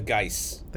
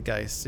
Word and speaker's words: geis: And the other thing with the Kresimir's, or with the, like, Bo's, the Gaia, what, how do you pronounce that? Gaia's? – Gaia's? The Geis. – The Geis, yeah.